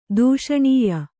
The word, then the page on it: ದೂಷಣೀಯ: dūṣaṇīya